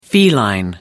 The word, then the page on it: feline: American English